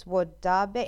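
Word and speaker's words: Wodaabe: It's Wodabe. It's W-O-D-A-A-B-E. Wodabe tribe?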